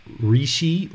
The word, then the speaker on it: Rishi: So in Thai tradition, we call Lusi, or the way that you'll hear it pronounced kind of in India is Rishi or Risi.